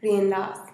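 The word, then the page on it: rinla'az: Teotitlán del Valle Zapotec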